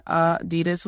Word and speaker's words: Adidas: If you actually think about culturally how Adidas are in culture, I mean, there's even a Run DMC song called My Adidas, which is the closest most people come to actually pronouncing it properly in most conversation, is that rap song.